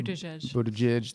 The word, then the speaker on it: Buttigieg: Mayor Buttigieg, I believe if I said that right. Maybe Buttigieg. – Thank you.